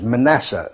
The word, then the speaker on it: Mannassah: My original name is Manasseh Lesser. M-A-N-N, well, the way we spell it is M-A-N-N-A-S-S-A-H.